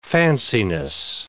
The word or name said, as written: fanciness